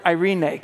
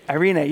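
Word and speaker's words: Irene (εἰρήνη): When we talk about this word peace, and Matt hit on this a little bit already, the Greek word is the word Irene. Can you say Irene? Irene. You don't have to remember that later, but just kind of get you a sense of how you say it.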